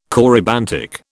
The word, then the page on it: corybantic: British English